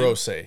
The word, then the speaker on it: Hirose: Do you think that, what is it, Akito Hirose? Is that how you pronounce his name? – Hirose, yeah.